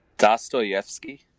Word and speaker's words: Dostoyevsky: It's Dostoyevsky. I can't ever get it right the first time, it's man. – Dostoyevsky. – There you go. That sounds way righter than anything I could have said.